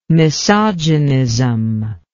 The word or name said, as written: misogynism